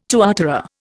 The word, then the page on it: tuatara: Indian